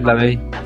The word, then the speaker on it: LaVey: Es Lavey, ¿verdad? O, ¿sí se pronuncia Sí, es Lavey.